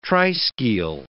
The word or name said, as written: triskele